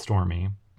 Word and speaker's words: Stormé: Yeah, it's spelled Stormé instead of Stormy, but she pronounced it Stormy.